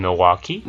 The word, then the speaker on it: Milwaukee: They did, in fact, just beat them in Milwaukee. – In fact, isn't Milwaukee an Indian name? – Yes, Pete, it is. Actually, it's pronounced Milwaukee, which is Algonquin for the good land.